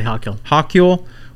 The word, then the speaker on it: Hochul: Yeah, Kathy Hochul.